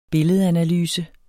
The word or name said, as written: billedanalyse